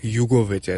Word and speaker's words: Jugovic: It's actually going to be pronounced Jugovic, I think.